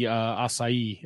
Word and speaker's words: Asahi: Oh yes, the belt, yes, but me pronouncing TV Asahi is terrible. – Is it Asahi? – Yeah, it's Asahi.